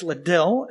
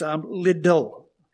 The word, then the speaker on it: Liddell: It's not pronounced Liddell, it's Liddell.